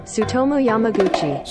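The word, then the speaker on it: Tsutomu Yamaguchi: How do you pronounce this? Sotum. – Sutomo Yamaguchi. – Sotomo Yamaguchi. – Sutomo Yamaguchi. – I, I think I said that.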